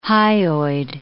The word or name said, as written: hyoid